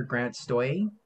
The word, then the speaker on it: Grant Stoye: And my guest for today, or for tonight, I should say, is comic writer Grant Stoye.